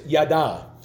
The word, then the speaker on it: yada: And the Hebrew word to know is yada. You hear people say yada, yada, yada. It's that word just pronounced differently.